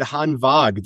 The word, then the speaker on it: Hanwag: I will say, dude, I love that you said Hanwag. That's the proper pronunciation of the brand.